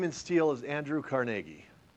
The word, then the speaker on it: Carnegie: Now, let me just, I used to live in Pittsburgh, and it actually is pronounced Carnegie. That's the correct pronunciation. How do I know? Because I got mocked by one of his relatives for saying Carnegie. If you see like a documentary about him, you'll see that the historians that are experts in him and in his life all pronounce it Carnegie, and the ones who don't know all pronounce it Carnegie.